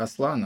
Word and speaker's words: Аслан: Я такой, а где все-таки будет правильное ударение на турецком? И оказалось, что в первоисточнике, если мы говорим про турецкие ударения, все-таки как Аслан оно читается.